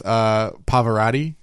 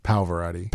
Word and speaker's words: Pavarotti: He is friends with Pavarotti, or who's the blind? – That's pronounced Pavarotti.